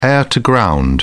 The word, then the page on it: air to ground: British English